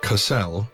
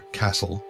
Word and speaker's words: Cassel: Cassel, never Castle, a common mispronunciation that also irritates the natives, is the resort world of the Goss system, the ultimate destination for hundreds of millions of human tourists every year.